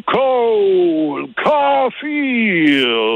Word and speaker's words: Cole Caulfield: Déjà, son premier dans la Ligue nationale, c'était Cole Caulfield.